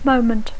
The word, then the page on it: moment: English